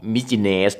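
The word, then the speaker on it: Mykines: มิจิเนส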